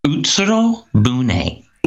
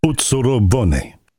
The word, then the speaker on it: Utsuro Bune: Utsuro Bune. – Utsuro Bune incident. – Yes, you can spell it. In English, you would spell it U-T-S-U-R-O hyphen B-U-N-E. – Utsuro Bune.